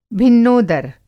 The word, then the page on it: भिन्नोदर: Hindi